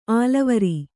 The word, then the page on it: ಅಲವರು: kannada